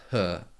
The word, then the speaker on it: Ho: So Dirty He, and it's spelled H-O, but really the name is pronounced He, certainly in Mandarin, I think, which I'm more familiar with.